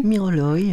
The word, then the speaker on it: miroloï: Comment on prononce ? Miroloï ? – Miroloï.